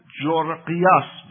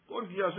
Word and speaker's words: گورگیاس: حالا لاتینش چیز دیگه ایست، اما وقتی این میاد به فارسی تبدیل بشه گورگیاس تبدیل بشه، اما ظاهراً جورقیاس بهش میگن. اون آقای فیثاغورسه هست، اون آقا، اونم تو همین تیپه. البته فکرش اینجوری نیست بنده خدا، متأ تلفظ اسمش خاصه میگم قورس. گورگیاس یا جورقیاس، هر کدوم که آقایون راحت بودن همون رو بگن. گورگیاس بهتره.